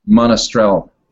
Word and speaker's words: Monastrell: Well, on that note, everybody out there, when you buy these books, go to Amazon or Montrestrel directly. How do you say it? – Monastrell. – Monastrell. I knew I was saying that wrong.